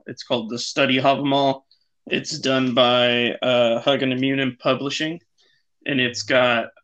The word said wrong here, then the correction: Hugin and Munin: I'm gonna need you to repronounce that, please. – Oh, you're a Hugin and Munin? – Yeah. – Sorry. – That stressed me out. – Sorry. Hugin and Munin Publishing.